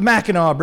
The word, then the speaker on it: Mackinac: It's pronounced the Mackinac Bridge, right? Even though there's a C on the end.